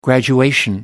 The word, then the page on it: graduation: American English